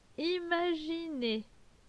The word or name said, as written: Imaginer